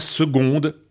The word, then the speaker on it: seconde: Est-ce parce qu'on prononce « seconde » avec le son « g », comme dans « gaillardise »,« gondole » et « bergamote »,« goguenardise » de la langue française?